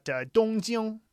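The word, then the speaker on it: Dongjing (东京): And you've got Dongjing, which is Tokyo. Tokyo is the Japanese pronunciation of the characters Dongjing.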